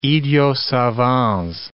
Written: idiots savants

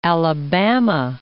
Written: Alabama

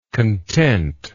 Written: contentness

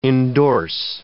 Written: endorse

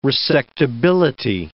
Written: resectability

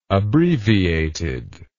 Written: abbreviated

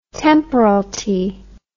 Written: temporalty

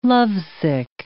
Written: lovesick

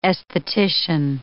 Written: aesthetician

